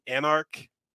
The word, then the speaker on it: Anarc: King Anarc.